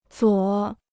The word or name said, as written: 左